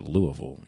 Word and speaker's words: Louisville: And you have to say it Louisville. You can't say Louisville. You got to say it like a local, Louisville, Kentucky.